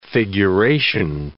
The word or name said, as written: figuration